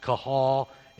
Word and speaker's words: kahal: And again, when I was taking Hebrew, this was one of the easy vocabulary words because it's actually pronounced kahal, and it means call.